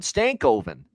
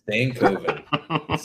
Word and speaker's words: Stankoven: We got to get you to pronounce that properly. He's on your team, for crying out loud. Stankoven. There you go. – Stankoven.